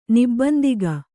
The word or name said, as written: ನಿಬ್ಬಂದಿಗ